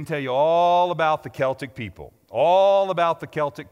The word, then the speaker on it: Celtic: So the first thing that I want to say to you today is that it's pronounced Celtic, not like the Boston Celtics, which, by the way, are ranked sixth in the Eastern Conference, which is better than the Nuggets this year.